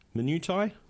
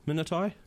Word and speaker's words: minutiae: So there's an app, and it's called I don't even know how you pronounce it. MINUTIAE. Minutiae. – Oh, minutia. – Minutia. – Minutia. – Oh yeah, there we go.